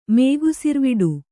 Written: ಮೇಗುಸಿರ್ವಡು

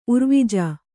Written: ಉರ್ವಿಜ

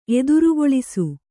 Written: ಎದುರುಗೊಳಿಸು

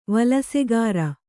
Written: ವಿಲಾಸಗಾರ

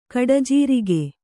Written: ಗುಡಜೀರಿಗೆ

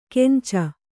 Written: ಕೆಂಚ

